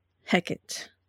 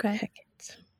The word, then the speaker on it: Hecate: In the Google pronunciations, YouTube pronunciations, the common thread seems to be the Shakespearean Hecate. – Okay. – Hecate.